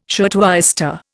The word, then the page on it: shirtwaister: Indian